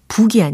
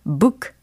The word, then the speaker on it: book: 책, book, 요거 연습해 보려고 합니다. book, book, book, 그죠? 북이 아니에요. 뭔가 치는 북을 떠올리시면 안 되고, 어, 보는 책을 떠올리셔야 하므로 발음은 book, book, book, 이렇게 해주시면 되겠습니다.